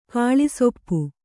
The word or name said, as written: ಕಾಳಿಸೊಪ್ಪು